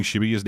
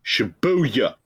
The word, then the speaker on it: Shibuya: Because I was doing the American thing of putting the emphasis on the U syllable. – Shibuya. – Yeah, whereas in this game, everyone calls it Shibuya. Which seems to be the more appropriate way to actually pronounce Japanese words like that.